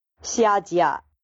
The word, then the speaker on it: Xiajia: I pronounce it as Shahia. Apparently, I was wrong, and it's pronounced... I'm going to play soundbite here and not even going to try to pronounce it again.